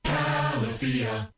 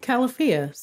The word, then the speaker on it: Califia: Califia. – So Califia. Soft Porn put the emphasis on the wrong syllable and he should own up to it.